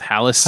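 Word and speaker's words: Hällas: And Hollis. I don't know if I'm pronouncing it right. It's H-A with like some squiggly lines on top. L-L-A-S. – Okay. – I go with Hollis.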